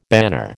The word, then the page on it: banner: American English